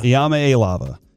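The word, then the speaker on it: Iamaleava: Iamaelava is how you say, according to him. That's what I watched a video that said that.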